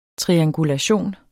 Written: triangulation

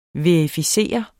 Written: verificere

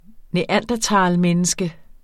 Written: neandertalmenneske

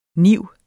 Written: NIV

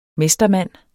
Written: mestermand

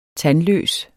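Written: TANDLØS

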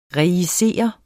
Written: rejicere

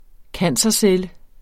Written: cancercelle

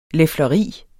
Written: lefleri

